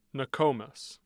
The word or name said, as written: nokomis